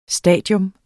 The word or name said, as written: stadium